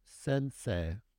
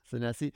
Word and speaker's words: Senesi: Says the ultra stud, Marco Sensei. – So, how do you pronounce it? – Senesi.